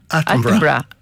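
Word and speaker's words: Attenborough: Από τον Ατένμπορο, ένα άνθρωπο για τον Ατένμπορο ή πόσο το σωστά η προφορά. – Ατένμπορο.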